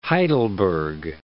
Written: Heidelberg

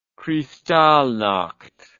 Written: Kristallnacht

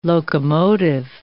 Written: locomotive

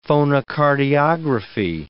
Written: phonocardiography

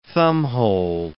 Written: thumbhole

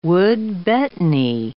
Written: wood betony

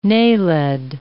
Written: naled

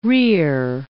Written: rear

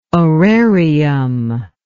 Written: orarium

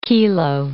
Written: kilo

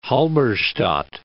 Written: Halberstadt

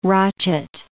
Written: rochet

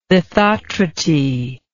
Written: lithotrity

